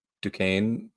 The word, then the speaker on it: Duquesne: I believe Duquesne. That's how you say it.